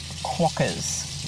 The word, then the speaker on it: quokkas: And also, the little cute furry creatures you were referring to are pronounced quokkas.